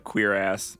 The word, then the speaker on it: cuirass: Y'all know how the C-U-I-R-A-S-S is pronounced? – Quijibo. – Like the armor. – What is it? – C-U-I-R-A-S-S. – Kuras? – Kuras? – Someone, I heard this somewhere. I recently someone like pointed out how that's actually pronounced. And not what I thought. – According to Google, it's just straight up queer ass.